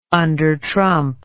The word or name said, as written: undertrump